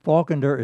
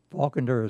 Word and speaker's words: Faulkender: Falkender is not like the other author, Falkener. Falkender is F-A-U-L-K-E-N-D-E-R, Falkender. – Okay. – And it's Hollandish, not German. And the Kender is K-E-N-D-E-R.